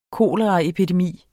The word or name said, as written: koleraepidemi